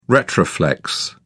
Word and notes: retroflex: British English

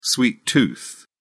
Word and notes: sweet tooth: British English

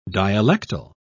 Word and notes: dialectal: British English

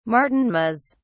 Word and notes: martinmas: British English

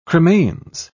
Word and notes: cremains: British English

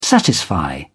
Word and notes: satisfy: British English

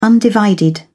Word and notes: undivided: British English

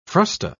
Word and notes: frusta: British English

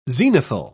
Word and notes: zenithal: British English

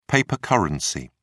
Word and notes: paper currency: British English

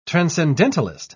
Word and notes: transcendentalist: British English

